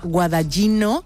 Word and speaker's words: Guadagnino: Fíjate, esta película está dirigida, espero decir bien el nombre porque tú esto lo vas a pillar muy rápido, por el director italiano Luca Guadagnino. – Bueno, a ver, no es como lo hubiese pronunciado yo porque soy de allí, pero lo has salvado bien. No está mal, no está mal, ¿no?